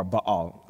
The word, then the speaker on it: Baal: And they begin to worship this god Baal, or Baal, as could also be pronounced.